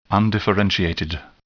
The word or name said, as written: undifferentiated